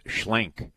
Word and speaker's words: Schlenk: Travis Schlenk. Rhymes with Schmank. – Schlenk, okay.